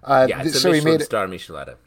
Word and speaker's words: Michelada: So he made Michelada. Is that how you pronounce it? – Yeah. – Like Michelin star. – Michelada.